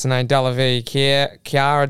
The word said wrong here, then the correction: Cara: It's Cara. – Cara Delevingne.